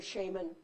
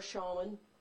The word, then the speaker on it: shaman: The word is pronounced either shaman or shaman. I tend to go back and forth. Either one is fine.